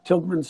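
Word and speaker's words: Tilghman: I never liked that. I thought I misspelled that when you look at it, but it isn't. That's how it's spelled. T-I-L-G-H. Tilgman Street. In Allentown.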